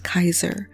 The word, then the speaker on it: Caesar: It wasn't until Latin that the C's started to make the soft C sound, so C's were pronounced as K's in ancient Greek. This would be true even for later Latin, so like how we pronounce Caesar for king, it would actually be pronounced Kaiser.